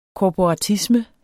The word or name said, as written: korporatisme